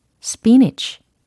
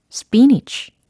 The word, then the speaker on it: spinach: S, P, I-N-A-C-H 라고 해요. 그래서 중간에 A라는 철자가 있으니까 스피나치 아닐까? 아니면 스피나크 아닐까? 라고 생각하시는 분들도 계신데 스피니치, 스피니치, 스피니치 라고 해주시면 됩니다.